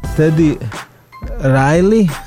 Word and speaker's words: Teddy Riley: My sme si mohli zahrať práve jednu, volá sa Listen Up, produkoval ju Teddy Riley, sa to vyslovuje.